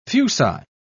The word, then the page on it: fuci: British English